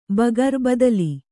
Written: ಭಕಾರ

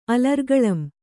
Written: ಅಲರ್ಗಳಂ

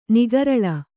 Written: ನಿಗರಳ

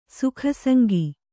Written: ಸುಖಸಂಗಿ

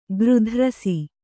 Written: ಗೃಧ್ರಸಿ

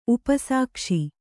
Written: ಉಪಸಾಕ್ಷಿ

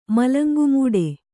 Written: ಮಲಂಗುಮೂಡೆ

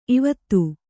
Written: ಈವೊತ್ತು

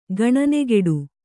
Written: ಗಣನೆಗೆಡು

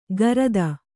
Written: ಗರದ

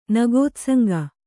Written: ನಗೋತ್ಸಂಗ

